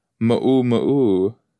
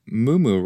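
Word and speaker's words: muumuu: Mu'u mu'u. Mu'u mu'u, maybe? Mu'u mu'u. Oh. Mu'u mu'u. Oh, wait, what? Oh, we're all pronouncing mumu wrong.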